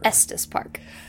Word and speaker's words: Estes: Estes Park. – You know what? – That's how you pronounce it. The way you say it sounds like testes.